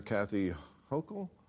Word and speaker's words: Kathy Hochul: What do you New Yorkers think of how Mayor Eric Adams and Governor Kathy Hochul? – Yes, you pronounced it right.